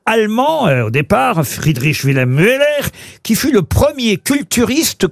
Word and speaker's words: Müller: Et, ou Müller. Ça s'écrit M-U-E-2-L-E-R.